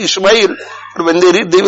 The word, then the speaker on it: Ismail: And similarly, when we give a good name to somebody and thereafter we mention the whole name, pronounce it correctly. Ismail, not Ishmael.